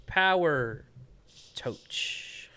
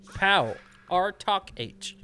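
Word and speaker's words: Pow R. Toc H.: Power Touch. – I kept reading it as Power Touch and then realized that it's not. – Pow. R Talk H.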